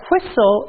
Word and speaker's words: whistle: In this one, the T is silent. But if we want to write whistle, it has to be with a T, but the T will be silent. So let's say whistle, and we don't hear the T sound.